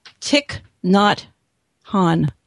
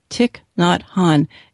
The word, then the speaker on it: Thich Nhat Hanh: The Art of Communicating by the Vietnamese monk that I love, and I'm learning how to say his name, Thich Nhat Hanh. Thich Nhat Hanh. It is not spelled like that at all. There's a whole bunch of extra consonants.